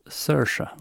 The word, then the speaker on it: Saoirse: Hon säger ju själv att det uttalas Saoirse Ronan.